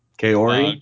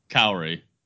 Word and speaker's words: Kaori: Kaori. Uh, Kaori.